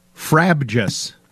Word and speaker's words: frabjous: It's an adjective, and the word of the day is fabjous. Nope. Frabjous. – Spell it. – Well, yeah. Use it in a sentence. F-R-A-B-J-O-U-S. Frabjous.